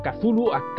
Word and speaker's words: Cthulhu: El tema Chulu o Cthulhu, como se pronuncia los pejigas que quien diga, pero yo le digo Chulu en el argot de Mancadi es Chulu, tío.